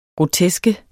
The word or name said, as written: groteske